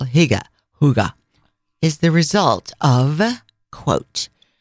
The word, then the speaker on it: hygge: So Tommaso and I are going to spend, as you just pronounced it, Hige, but technically, even though I cannot speak Danish, cannot pronounce it, but it's pronounced more Huga, not Hige.